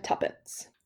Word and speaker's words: Tuppence: And so their names are Tommy and Tuppence. – Sorry, what was that second one? – Tuppence. – Can you spell that for me? – They spell it T-U-P-P-E-N-C-E.